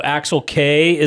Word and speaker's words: Axel Kei: I know Axel Kei is the guy that everybody's excited about, and that is the pronunciation of his name, but everybody tells me Jude Wellings is a baller and he's – off with one of the U.S.